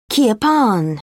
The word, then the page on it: kirpan: British English